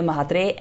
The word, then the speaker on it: Mhatre: I've got Aditya Mhatre and Abhishek Kumar from Indicast.